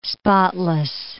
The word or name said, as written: spotless